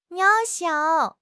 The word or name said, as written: 渺小